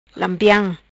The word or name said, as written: Lampiam